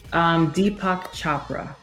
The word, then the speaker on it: Deepak Chopra: Deepak Chopra.